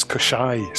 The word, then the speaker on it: Koschei: Is that how it's pronounced? That's interesting, because I've always pronounced it as Koschei in my head. That's yes. – Very interesting. – Well, the author has confirmed it's Koschei, so...